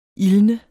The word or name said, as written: ildne